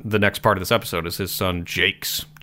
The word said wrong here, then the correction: Jacques: I never knew how to pronounce that. – Jacques. – Jacques? How dare you? That's. Oh, sorry. Jacques? – Jacques. – Mm-hmm. Okay, cool. – No S sound on the end.